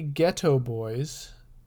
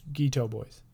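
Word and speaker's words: Geto Boys: I think it's supposed to be Ghetto Boys, but okay. it's spelled G-E-T-O. Yeah. So it's Ghetto Boys.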